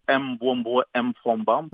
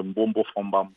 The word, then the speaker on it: Mbombo Fombam: Si on regarde mes deux noms, il y a MB, il y a MF. Ben, les personnes vont chercher à prononcer Mbombo, Mfombam. – Ouais. – Les deux M sont muets, c'est Mbombo Fombam.